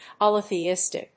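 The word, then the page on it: poly-theistic: pol・y・the・is・tic; pὰliθiːístɪk (en-us); p`ɔliθiːístɪk (uk)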